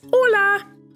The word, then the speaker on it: hola: That's right, it's hola. Now, you spell it H-O-L-A, but the H in Spanish is silent.